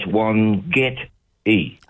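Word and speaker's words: Wangetti: The first three letters, W-O-N, Wan, and then you emphasise the get. So it's Wangeti.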